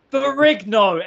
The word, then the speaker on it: Ferrigno: Ferrigno.